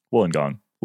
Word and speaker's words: Wollongong: But our our our podcast editor, who is Australian, said it's like woolen woolen gong, basically woolen gong.